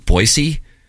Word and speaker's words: Boise: Scott Van Pelt calls it Boise. And I believe that's actually the proper pronunciation. – It's getting too much out of the S. – That's how I feel, too. – We kind of have a Z worked in there for us.